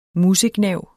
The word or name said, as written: musegnav